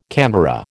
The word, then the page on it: camera: American English